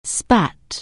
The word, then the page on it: spit: anglais